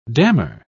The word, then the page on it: dammar: British English